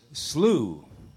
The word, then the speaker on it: slough: What doesn't it rhyme with? – It does not rhyme with cough. – Doesn't rhyme with cough. Slew. S-L-E. Slew. S-L-O-U-G-H.